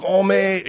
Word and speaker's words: Hommes: It ain't Vogue Homo Japan, okay? H-O-M-M-E-S. How do you figure that's homo? – Well, I mean, it means homo.